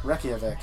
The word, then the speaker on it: Reykjavik: Reykjavik, yeah.